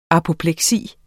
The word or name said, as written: apopleksi